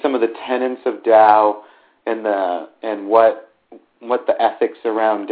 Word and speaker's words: Tao: The Tao, or Tao, it's spelled T-A-O, and sometimes it's pronounced Tao.